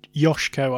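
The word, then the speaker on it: Joško: Oh, it's a soft S, so it's Josko, I suspect.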